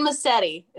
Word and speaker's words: Massetti: Nobody ever gets my last name right. Yes, it's Lauren Massetti. It's not even spelled phonetically correct.